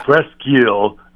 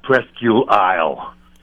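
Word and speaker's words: Presque Isle: It's Presqu'île, not Presqu'île Isle. – Jesus. – It's just Presqu'île. – Okay, thank you. – Presqu'île. I know it's spelt Isle, but it's eel.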